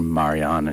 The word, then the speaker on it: Marianne: And that is titled, the track is titled Marianne. Or Marianne, and you're going to hear that's their pronunciation, the Irish.